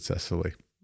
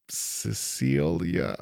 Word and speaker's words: Cecily: It's excellent that you said that, Cecily, because I would not have pronounced it Cecily. – What would you have said, do you think? – Cecilia, something along that line. It wouldn't have been Cecily, so it's fine.